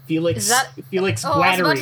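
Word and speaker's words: Félix Guattari: But yeah, so today's today's slightly or much less structured session was brought to you by Feli Guattari. – Feli Guattari. – Feli. – Felix Guattari.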